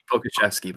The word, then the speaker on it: Pokuševski: and Alexei Pokuševski. I don't know how to say his name, but, you know, the rookie there as well. – It's Pokuševski, but you basically got it.